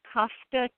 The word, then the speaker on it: Kafka: My practice is Kafka Care Medical.